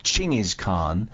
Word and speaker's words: Chinggis Khan: Nelson on this, on how to pronounce Genghis Khan, the correct pronunciation, says Nelson, is approximately Chinggis Khan. He's got an apostrophe between the G and the Z, in which the CH is partway between an English J and CH, and the apostrophe is a glottal stop, and the KH is pronounced as in the Scottish loch.